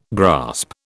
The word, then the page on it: grasp: British English